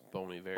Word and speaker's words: Bon Iver: You know it's pronounced Bon Iver. – Bon Iver, whatever.